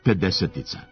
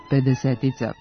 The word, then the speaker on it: pedesetica: Da, trebalo bi da se izgovara pedesetica po analogiji s rečju desetica. Akcenatske greške su veoma rasprostranjene i najčešće nastaju pod uticajem analogije. U rečniku srpskog jezika odrednica pedesetica navodi se samo pod kratkouzlaznim akcentom na trećem slogu.